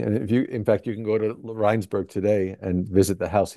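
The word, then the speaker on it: Rijnsburg: Rheinsberg, yes. It's the Dutch pronunciation.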